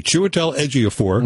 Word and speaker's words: Chiwetel Ejiofor: Donald Glover as Simba, Chiwetel Ejiofor, one of my favorite actors and favorite actors' names, because I can pronounce it correctly, Chiwetel Ejiofor.